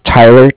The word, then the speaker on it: Tyler: My name is Tyler, T-Y-L-E-R. And I'm calling with Cardiff, it's spelled C-A-R-D-I-F-F, as in Frank, Frank.